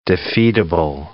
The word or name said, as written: defeatable